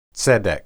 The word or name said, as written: tsedek